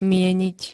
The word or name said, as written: mieniť